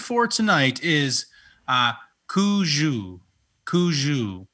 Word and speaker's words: cuju: C-U-J-U. Like Cujo with a U.